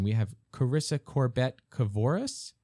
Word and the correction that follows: Carissa: Almost all the way there. It's just two syllables, just Carissa. – Carissa.